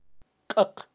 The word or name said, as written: kaq